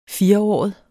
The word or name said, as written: firåret